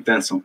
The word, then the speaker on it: Venson: Antes da gente começar, eu pronunciei seu nome corretamente? Venson? Sim, Venson.